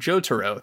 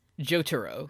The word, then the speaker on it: Jotaro: Sorry, for this episode, we've been saying it wrong. Jotaro, there we go. – Jotaro.